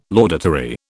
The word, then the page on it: laudatory: British English